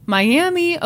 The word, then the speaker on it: Miami: Just an FYI, Miami, Oklahoma is pronounced Miami. What? LOL. My okay, M Y a M M U H.